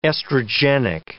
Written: estrogenic

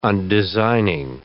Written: undesigning

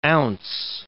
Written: ounce

